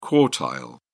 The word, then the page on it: quartile: British English